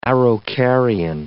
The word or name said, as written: araucarian